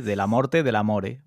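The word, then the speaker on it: Dellamorte Dellamore: A ver si lo digo bien. – ¿Vale? – De la morte del amore. – Bueno, pero con coma en medio. De la morte del amore. ¿Bien? – Está bien pronunciado, sí.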